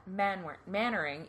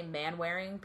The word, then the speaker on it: Manwaring: That's how you pronounce it in the English. Mannering. Even though I always want to say Manwaring because that's how it's spelled. – That's literally how it's spelled. But it's Mannering.